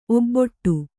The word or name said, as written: ಒಬ್ಬೊಟ್ಟು